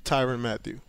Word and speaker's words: Tyrann Mathieu: Tyrann Mathieu.